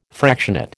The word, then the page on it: fractionate: American English